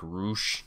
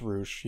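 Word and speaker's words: Rusev: With uh, Dalton Castle losing in 15 seconds to, I know it's spelled Rush, but they said it like Roosh. It's Roosh, yeah.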